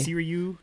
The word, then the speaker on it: Sirui: For a very long time, I used one of those Korean manufactured Siri, I think is how it's pronounced. – Mm, yeah. – It's spelled Siri. – Yeah, exactly. S-I-R-U-I. – Siri.